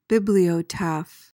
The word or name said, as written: bibliotaph